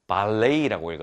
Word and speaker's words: ballet: 발레이라고 읽었죠. 우리가 흔히 알고 있는 이 발레이입니다. 발레. 저는 발레를 잘 못하지만요. 그래서 발렛 같은데요. 이게 발렛 같은데 발레이, T 발음이 무음입니다. 그래서 발레이라고 읽어야 된다는 점 알려드립니다.